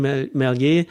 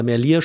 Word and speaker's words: Merlier: Aber am Ende haben wir gesehen, auch heute Tim Merlier oder Merlier spricht man es, glaube ich, aus, das war wirklich ein kraftvoller Sprint, den er da heute nochmal hingelegt hat.